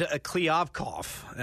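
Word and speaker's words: Kliavkoff: And, you know, you mentioned George Kliavkov, as now we know. It's a three-syllable name, so that's how I'll always remember it. – Did I get that right? – You did. Okay.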